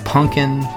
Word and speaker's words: Punkin: That's Punkin, not Pumpkin.